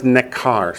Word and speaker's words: nekar: That word foreigner is nekar. Spell it N-E-K-A-R, nekar.